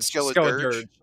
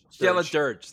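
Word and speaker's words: Skeledirge: Now, Skeledrig is awesome. – Isn't it Skeledurge? – Skeledurge, thank you.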